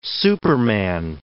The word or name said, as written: superman